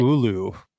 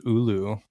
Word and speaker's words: Olu: Just three picks later, Olu Olawatimi out of Michigan, the center. – It's actually Ulu. It's Ulu. The Seahawks put out a pronunciation guy.